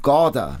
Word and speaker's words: Gaarder: Und es geht um Sophies Welt von Jostein Gaarder.